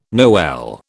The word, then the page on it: Noel: British English